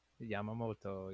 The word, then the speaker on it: Yoshinobu Yamamoto: Yoshinobu Yamamoto. – Yamamoto, yeah.